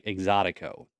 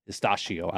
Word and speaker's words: Exotico: So again, I think I've been saying it wrong the whole weekend. I was pronouncing it something totally different. And then I realized, it's like Exotico, not Estacio.